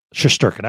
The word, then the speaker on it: Shesterkin: Shesterkin, I was right.